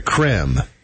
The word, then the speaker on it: Creme: It is C-R-E-M-E. And so, does that mean it's a creme? – Yes. – Egg. Mm-hmm. And last week, the company sparked outrage over the pronunciation of its beloved Easter product when a Sweet Tooth fan tweeted Cadbury asking the correct way to pronounce C-R-E-M-E egg. They replied that it's pronounced as creme.